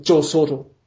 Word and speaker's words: Joe Soto: Aber es sind nur drei Silben. Joe Soto.